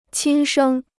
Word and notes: 亲生: qīn shēng

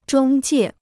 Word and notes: 中介: zhōng jiè

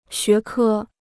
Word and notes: 学科: xué kē

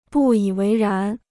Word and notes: 不以为然: bù yǐ wéi rán

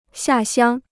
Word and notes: 下乡: xià xiāng

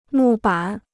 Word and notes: 木板: mù bǎn

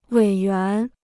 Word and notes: 委员: wěi yuán

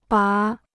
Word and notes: 拔: bá

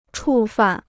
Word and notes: 触犯: chù fàn

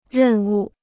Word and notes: 任务: rèn wu

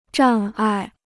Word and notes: 障碍: zhàng ài

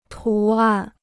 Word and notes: 图案: tú àn

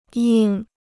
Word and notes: 硬: yìng

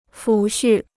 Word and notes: 抚恤: fǔ xù

